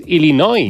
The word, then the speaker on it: Illinois: Se pronuncia Illinois. – Sí, perdón, perdón, sí. – Illinois. – Illinois. Pues yo digo Illinois y no entendemos todos. Porque como diga Illinois, nadie lo entiende.